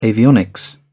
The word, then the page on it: avionics: British English